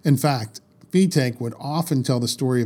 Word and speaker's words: Fetaque: In regards to the pronunciation of his name, from the January 1938 issue of the Linking Ring magazine, it says Fetaque, who insists that his name be correctly pronounced, like B Take, is a strong believer in showmanship in preference to a stage full of apparatus, and how well he proves his point.